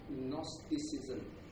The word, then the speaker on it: Gnosticism: As I mentioned earlier on, the Colossians were faced with Gnosticism. Okay, for those of you who are taking note, it's spelled G-N-O-S-T-I-C-I-S-M. G-N-O-S-T-I-C-I-S-M.